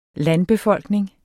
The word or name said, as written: landbefolkning